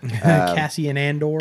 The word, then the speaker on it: Cassian Andor: Cassian Andor.